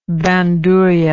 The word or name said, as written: bandurria